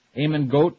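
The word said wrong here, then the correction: Amon Göth: is that how you say his name? – Get. – Eamon Get? – Amon Get.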